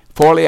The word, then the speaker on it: Foley: I got home preaching at a wonderful little church in Forley, Foley, I think I pronounced that right, Foley, Alabama. That's halfway, somewhere like halfway between Pensacola and Mobile.